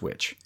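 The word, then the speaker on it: VVitch: I mean, it is the witch. It's pronounced witch.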